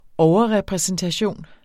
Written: overrepræsentation

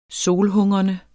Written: solhungrende

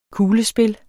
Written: kuglespil

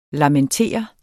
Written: lamentere

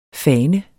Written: fane

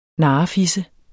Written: narrefisse

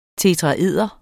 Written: tetraeder

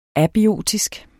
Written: abiotisk

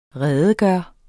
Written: redegøre

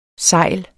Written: SEJLENES

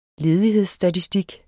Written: ledighedsstatistik

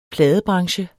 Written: pladebranche

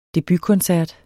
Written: debutkoncert

